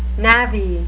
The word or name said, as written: navvy